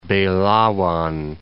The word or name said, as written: Belawan